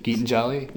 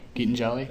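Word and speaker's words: Gitanjali: The end rhymes with Ollie. – Geetan Jolly? – Yeah, that's it. – Geetan Jolly.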